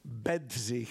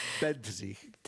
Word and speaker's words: Bedřich: Aber wie spricht man den Vornamen korrekt aus? – Bedřich. – Ah, danke. – Bedřich.